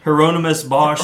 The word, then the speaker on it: Hieronymus Bosch: Hieronymus Bosch Hieronymus painting Bosch.